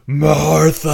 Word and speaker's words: Martha: Och det är väl så att Martha och Bruce tror jag där. Är det inte så en sån? – Ja, men det uttalas Martha!